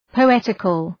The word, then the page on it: poetical: pəʋ’etıkəl